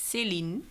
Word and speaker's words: Celine: I say my name like Celine. – Celine. Because it's more Celine. Emphasis on the second part, right? – Yeah.